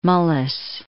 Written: Mullis

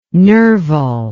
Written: Nerval

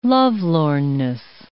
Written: lovelornness

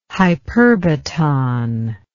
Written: hyperbaton

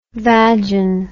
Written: vagin

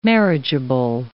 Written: marriageable

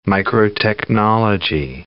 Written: microtechnology

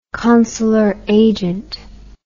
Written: consular agent

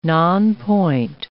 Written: nonpoint

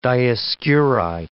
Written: Dioscuri

